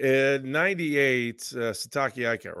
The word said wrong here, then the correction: Siaki Ika: Siaki. Siaki. His first name is Siaki. – What did I say? Ika. – It's Siaki Ika.